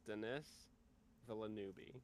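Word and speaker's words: Denis Villeneuve: I I believe it's Dennis Villeneuve.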